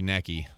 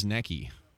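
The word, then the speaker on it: Necchi: Oh, probably Necky. That's Italian. White sewing machine in a case. It's the Royal Series Necky model, right?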